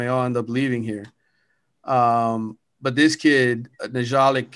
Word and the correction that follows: Nyjalik: Kelly, I'm gonna there you go, Nyjalik Kelly, I think is the way you pronounce it out of Fort Lauderdale Dillard.